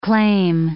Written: claim

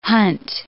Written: hunt

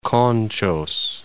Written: Conchos

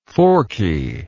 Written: forkiness

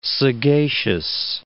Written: sagacious

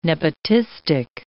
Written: nepotistic

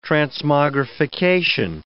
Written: transmogrification

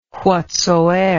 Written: whatsoeer

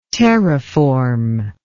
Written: terraform

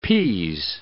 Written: pease

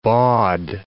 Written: bawd

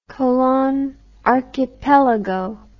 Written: colon archipelago

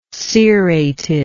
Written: cerated

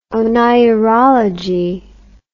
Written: oneirology

